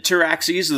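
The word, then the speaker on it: Pteraxii: As they said, Taraxis, the P is silent, just like in Pterodactyl.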